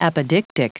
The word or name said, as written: apodictic